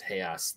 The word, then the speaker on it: real: When Paul Giamatti says 5,000 real, it's pronounced heas.